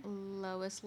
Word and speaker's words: Lois: There was Lewis. How do you say her name again? – Lois Lowry.